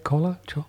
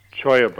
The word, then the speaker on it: cholla: This Chola, Cholla. How do you say it? Cola? – Choya buds.